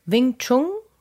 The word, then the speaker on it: Wing Chun: Und ähm, ich sitze hier mit Pino Maurizio Keppner, Potenzialentfalter, wie er sich selber nennt, und Wing Chung.